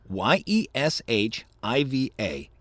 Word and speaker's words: yeshiva: Y E S H I V A. Yeshiva? That looks sounds right.